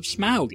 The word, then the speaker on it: Smaug: S-M-A-U-G, smug. In American English, at least, A-U almost always says aw, or maybe ah, as in laugh, but it's almost always an aw sound, smog. And yet, everybody in this movie pronounces it smaug.